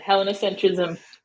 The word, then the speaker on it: Hellenocentrism: So we used to play around with different words, but Hellenocentrism is how I pronounce it, this idea of putting Greece at the center.